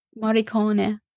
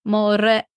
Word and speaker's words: Morricone: Ennio Mori, Morricone. Morricone? That's definitely wrong. Okay, guys. – Sony? Coney? – Morricone? Cone? It's Morricone. And roll the R's. Imagine the R's rolled. Morricone.